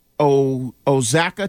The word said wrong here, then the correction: Oaxaca: cheese. – Oaxaca.